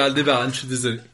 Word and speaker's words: Aldébaran: que ça se prononce Aldébaran et du coup peut-être faire un choix de leur dire Aldébaran.